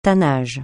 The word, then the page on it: tannage: francais